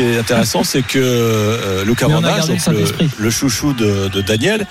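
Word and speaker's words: Van Assche: Vandache. On dit Vandache. – Je sais pas pourquoi, depuis aujourd'hui, l'entourage a décidé de changer la prononciation, alors qu'au club, depuis toujours, vous avez toujours été Vandache.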